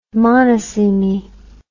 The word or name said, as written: monosemy